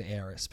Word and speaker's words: Aerith: And it's spelled Aerith, but I said Aeris.